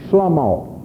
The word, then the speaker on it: Shlomo: You pronounce Solomon in the Hebrew as Shlomo.